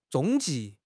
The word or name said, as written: 踪迹